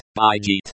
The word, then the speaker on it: бежит: Бежит. Pronounced, bijit, runs, flows.